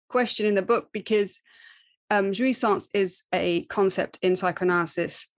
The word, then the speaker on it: jouissance: And I think you refer to it in the book as jouissance, I believe is how you say it.